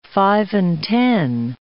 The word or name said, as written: five-and-ten